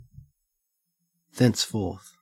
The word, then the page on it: thenceforth: Australian English